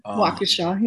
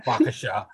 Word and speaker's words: Waukesha: I mean, we have Kenosha, we have Waukesha, and I hope I said that name right. – Waukesha, yeah. – Waukesha. And okay, I'll say it right from now on.